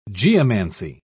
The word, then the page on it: geomancy: British English